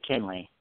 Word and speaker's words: Kinley: Yeah, you did, Kinley.